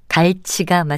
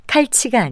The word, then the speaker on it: 갈치: 대표적인 것으로 흔히 칼치라고 부르는 생선은 갈치가 맞습니다. 생선 모양이 칼처럼 생겨서 그렇게 부르곤 하는지 아니면 둘째 음절의 치의 영향을 받아서 첫째 음절을 거센 소리로 칼로 발음하는 것인지는 확실치 않지만 칼치가 아니라 갈치가 맞습니다.